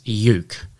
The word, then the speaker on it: yeuk: And this week's Word of the Week is yuk, and that is spelt Y-E-U-K.